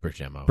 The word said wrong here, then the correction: Bermejo: Bermejo. That's okay.